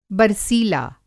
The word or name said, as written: बरसीला